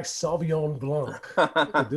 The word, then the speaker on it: Sauvignon Blanc: Mark said it right. Sauvignon Blanc. – This. He said it with a French accent and shit.